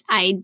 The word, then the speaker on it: Aede: It's it's pronounced aid, but it's spelled A E D E. Is that right?